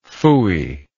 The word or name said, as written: pfui